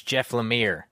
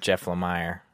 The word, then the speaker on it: Lemire: So it's Jeff Lemire. – Uh-huh. – Not Jeff Lemire.